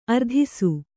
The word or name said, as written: ಅರದೇಸಿ